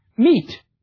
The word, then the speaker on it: meet: Yet, if you were really educated in Middle English, you would be writing it as M-E-E-T. Meet. In the age of Chaucer, that word would have been pronounced meet.